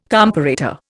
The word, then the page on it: comparator: Indian